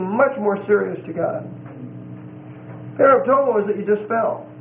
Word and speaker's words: paraptoma: The next word is paraptomo. Paraptomo. P-A-R-A-P-T-O-M-A. And it's a long O.